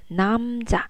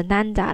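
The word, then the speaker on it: namza (남자): 刚学发音的时候，会把“ 男子”“ 男人” 这个词 “namza” 这个嗯，对吧？闭不闭起来就变成了 “nanda” 了，哎，完全两个意思，不要闹这种笑话哦。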